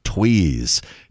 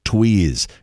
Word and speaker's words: Tewes: Tweez. T-E-W-E-S. And for some reason, she pronounced her name Tweez.